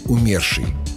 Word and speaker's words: умерший: Его написали специально, чтобы запомнить правильное ударение. «Он был какой-то потускневший, как лебедь в опере умерший».